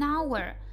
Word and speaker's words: hour: К примеру, She was riding for about an hour. An hour – один час. Слово hour читается и пишется H-O-U-R. you are. Our. Буква H не дает никакого звука, и само слово начинается со звука А.